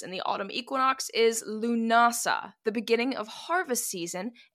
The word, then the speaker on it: Lughnasadh: All right, it is L-U-G-H. N-A-S-A-D-H. So phonetically in English, it looks like Lugnasad. But luggily for us, it's just pronounced Lunasa.